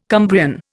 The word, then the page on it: Cumbrian: Indian